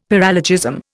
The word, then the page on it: paralogism: Indian